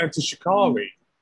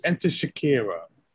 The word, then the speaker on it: Enter Shikari: He even interviewed Enter Shikari, who I actually know myself as a band. I saw them in Aylesbury back in 07, and he mispronounced them as Enter Shakira, of all people.